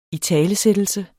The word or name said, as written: italesættelse